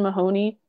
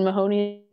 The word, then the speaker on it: Mahoney: So on TikTok and Instagram, I'm Musician Mahoney, and Mahoney is spelled M-A-H-O-N-E-Y.